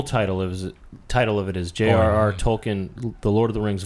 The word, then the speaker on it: Tolkien: How do you say that? T-O-L-K-I-E-N. Tolkien? – Tolkien, I guess. – I think Tolkien.